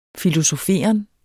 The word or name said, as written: filosoferen